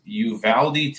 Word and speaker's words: Uvalde: Uvalde, Texas.